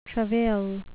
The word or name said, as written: travail